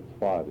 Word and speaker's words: Safed: You know where Tzfat is. – Tzfat. – Tzfat, S-A-F-E-D. – Right. – S-A-F-E-D.